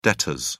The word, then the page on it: debtors: British English